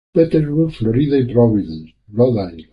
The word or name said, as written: Florida